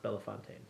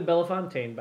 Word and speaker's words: Bellefontaine: Your city is pronounced Bellefontaine. – Well, what's the name of their club? – I think they're the Bellefountain Body Bearders. – Nope, they're the Bellefontaine Body Bearders.